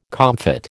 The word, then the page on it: comfit: American English